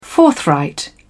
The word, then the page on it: forthright: British English